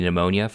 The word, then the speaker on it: pneumonia: Another podcast you should check out from us is episode 46, Community Pneumonia, Free Current Jelly. – Yes, that is the episode title. – And not only do we talk about in that episode why the P is silent in pneumonia, we also talk about the disposition antibiotics and the port PSI score, which is going to be on your boards.